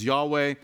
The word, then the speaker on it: Yahweh: And again, when you see all capital letters for Lord, it is a reference to the covenant name of God, which is, we think the best way to pronounce it is Yahweh.